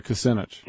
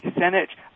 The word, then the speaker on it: Kucinich: I would like to be for I can't even say his name, Kucinich. – Yeah, Kucinich. – Kucinich.